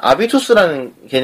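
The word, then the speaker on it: habitus: 그래서 나중에 이 사람이 나중에 이제 학문적으로 독립을 하고 나서 만든 개념이 이제 아비투스라는 개념이 있어요. – 예. – 아비투스. 뭐 프랑스어에서는 이제 영어 알파벳의 H가 이제 이응 발음이 나기 때문에 그 때문에 영어로 치면 habit이죠.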